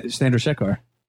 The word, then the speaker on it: Chandrasekhar: Jay Chandrasekhar. – How's that pronounced? – Jason Chandrasekhar. – That's pretty good.